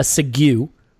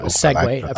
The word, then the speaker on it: segue: Let's ask a question that relates to that in what we call in the business a segue or a segue. I've heard it pronounced both ways.